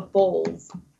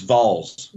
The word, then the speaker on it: Vols: I have a friend that they call herself the Bulls. – It's Vols. – But it's Vols. – Yeah, V-O-L-S. – Vols. I've known her for 13 years, and she pronounces it Bulls.